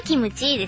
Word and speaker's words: キムチ: 韓国はキムチですね。 – キムチ。 – ムを発音するときは強く唇を締めて言うのがコツらしい。